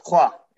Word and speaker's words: Troyes: It's pronounced Troyes in France, like the number three, but it's Troyes.